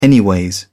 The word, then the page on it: anyways: British English